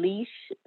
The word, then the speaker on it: Lish: It's a little lengthy, but it's Queen Leash the Realtor. And Leash is spelled L-I-S-H. So it's Q-U-E-E-N-L-I-S-H the Realtor.